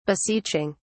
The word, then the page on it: beseeching: British English